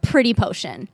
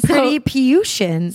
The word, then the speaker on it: PRTTYPEAUSHUN: One is first of all, I'm going to say, first and foremost, it took me like 2 years to figure out how to pronounce this. It's PRTTYPEAUSHUN. Pronounced Pretty Potion. Hate the name. Love the product. – Pretty Potion.